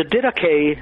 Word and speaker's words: Didache: Well, the Didache, that's spelled D-I-D-A-C-H-E, the Didache is